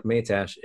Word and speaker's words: Matiash: Although it sounds like Matash, it is spelled like M-A-T-I-A-S-H.